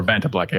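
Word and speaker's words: Vantablack: It is a sword made out of some black material that is almost completely dark, like Fanta Black or Vanta Black. I always say it wrong.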